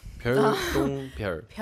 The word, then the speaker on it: 별똥별: 왜 이렇게 자꾸 똥을 강조하시는 거죠? 별을 더 강조하세요. – 그 발음이 아, 네. 그러니까 맨 앞에 얘기했을 때도 별 별똥별. 그러니까 그 쌍디귿 들어가잖아요. – 네. – 맨 앞에 별이라고 발음하게 될 때도 있어요. 아, 이게 오. 신경 쓰려고 하다 보니까. – 아, 별똥별 이렇게. 네. 아. – 더블 디귿이니까.